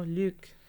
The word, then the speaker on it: Luc: I've never said his name right either. – There you go. – I've just said Luc Besson. – No, Luc.